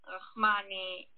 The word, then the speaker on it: Rachmani: Okay, so this one, and then Rahmani is spelled like Rachmani. It's R-A-C-H-M-A-N-I. So it's TAL_RACHMANI. Tal Rahmani.